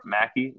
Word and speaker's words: McKie: _ Mackie, which is spelled MCKIE.